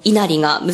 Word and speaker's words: 稲荷: どうして狐とこの稲荷が結びついたんでしょうか。 – そうですね、まずはじめにこの稲荷、稲の荷物の荷と書いて稲荷と呼んでいますけれども、